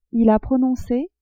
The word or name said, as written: il a prononce